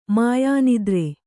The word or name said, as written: ಮಾಯಾನಿದ್ರೆ